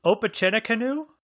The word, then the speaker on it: Opechancanough: Opechancanough? – Yeah, and the uncomfortable laughter is actually the second syllable, so you got that perfect.